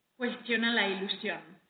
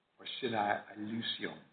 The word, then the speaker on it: Cuestiona la ilusión: That song is hypnotic, and I understand I didn't quite pronounce it right, so why don't you say it? – Cuestiona la ilusión. – Cuestiona la ilusión.